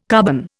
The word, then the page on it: carbon: Indian